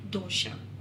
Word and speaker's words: dosa: I don't really know if this is the right answer because in Kerala, we call it dosha. Okay. It's more popular as dosa or dosa,